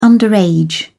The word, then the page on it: underage: British English